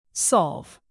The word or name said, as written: solve